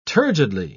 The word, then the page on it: turgidly: British English